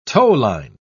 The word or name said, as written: towline